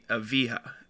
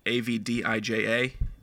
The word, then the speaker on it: Avdija: Denny Avija. I'm assuming it's a silent D, Avdija.